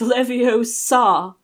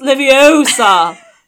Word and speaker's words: Leviosa: You're putting the stress on the wrong syllable. Actually putting the stress on the wrong syllable. So basically she comes in and goes, it's Leviosa. No, it's Leviosa, not Leviosa, you know, and then voila, you know, she wakes up three fucking guys.